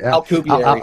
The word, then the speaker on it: Alcubierre: I say Alcubierre, but... – Alcubierre. – How do you... – Alcubierre. – Yeah. Okay, I'm gonna say it from now that way from now. Alcubierre.